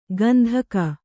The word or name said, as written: ಗಂಧಕ